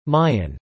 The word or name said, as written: Mayan